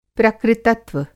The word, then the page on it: प्रकृतत्व: Hindi